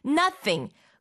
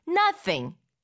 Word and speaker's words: nothing: Nothing 흔히 nothing이라고도 우리 글로 표기하기도 하는데 자 발음할 땐 나 입을 크게 벌리고 나 그 다음에 혀 끝을 윗니 아랫니 사이에 살짝 댔다가 떼듯이 하면서 th 이 발음을 만듭니다. Nothing, nothing 그 어떤 것도 아니다라는 게 이미 그 안에 다 들어가 있어요.